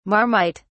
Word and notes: marmite: British English

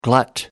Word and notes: glut: American English

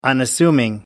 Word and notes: unassuming: American English

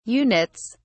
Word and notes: units: British English

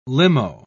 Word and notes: limo: British English

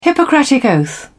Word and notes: hippocratic oath: British English

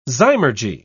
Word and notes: zymurgy: British English

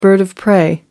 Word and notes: bird of prey: American English

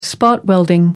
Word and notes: spot welding: American English

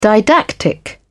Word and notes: didactic: British English

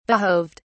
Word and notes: behoved: British English

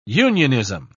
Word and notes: unionism: British English

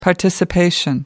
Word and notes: participation: American English

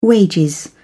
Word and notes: wages: American English

